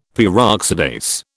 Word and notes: Peroxidase: British English

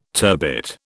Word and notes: turbit: British English

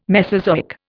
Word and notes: Mesozoic: Indian

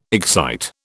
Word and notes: excite: British English

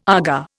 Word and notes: Aga: Indian

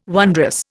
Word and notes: wondrous: Indian

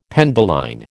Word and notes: penduline: American English

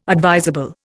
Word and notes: advisable: Indian

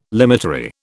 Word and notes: limitary: British English

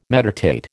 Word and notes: meditate: American English

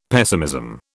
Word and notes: pessimism: British English

